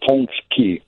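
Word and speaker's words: pączki: The singular would be Punchek. – More Punchek than Punchek. – And there's it's not really an N. It kind of just goes nasal. French has a similar pronunciation. Ponchek and Ponchki.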